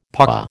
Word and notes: paua: American English

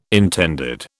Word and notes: intended: British English

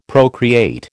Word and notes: procreate: American English

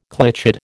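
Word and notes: clichéd: American English

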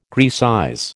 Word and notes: Graecize: American English

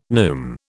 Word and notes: neume: British English

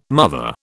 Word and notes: mother: British English

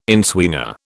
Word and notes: inswinger: British English

